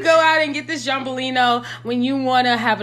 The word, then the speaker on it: Giambellino: Did I forget how to pronounce this? Giambellino from Aldi. $8.99. Okay, make sure you know you say it like that.